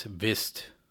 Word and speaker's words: vidst: Vist nuk means probably, but it's spelled V-I-D-S-T or V-I-D-S-T space N-O-K. But we don't pronounce the D, it's Vist.